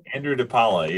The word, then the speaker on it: DePaola: Is that how you pronounce it? – Andrew DePaola, yeah.